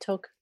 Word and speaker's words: toque: Am I saying that right? Toque or toki? – Yes. No, you're saying it right. Toque.